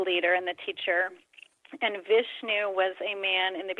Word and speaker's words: Vishnu: I'm not sure if I'm pronouncing her name correctly. And Vishnu, who were they in your life? – You are pronouncing them correctly.